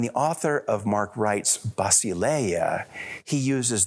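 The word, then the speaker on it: basileia (βασιλεία): You know, the Greek word used in the New Testament for empire is basileia, and for George Theodore's benefit, in modern Greek, it's pronounced vasileia, like it starts with a V, but it really starts with a B.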